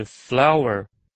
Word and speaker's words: flour: 句子中的 flour 就表示面粉的意思，这个字的发音跟花朵的念法一样，都是 flour。